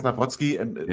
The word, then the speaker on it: Nawrocki: Polnit wäre es Nawrocki. Aber also wir können auch Nawrocki sagen, mir ist es komplett egal.